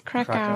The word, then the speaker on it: Krakauer: Pronounce it's Krakauer.